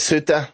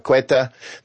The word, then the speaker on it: Ceuta: Some people call it, I call it Ceuta, which is what a lot of people call it, and others call it Quetta, but Ceuta is what I call it, and that's what a lot of people call it, Ceuta, okay. Right? So it's spelled C-E-U-T-A, Ceuta, right?